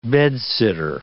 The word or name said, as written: bed-sitter